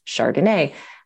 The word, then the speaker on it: chRDNA: So we wrote that out as a little acronym, chRDNA, which we pronounce Chardonnay.